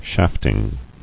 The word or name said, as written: Shafting